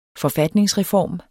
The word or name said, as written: forfatningsreform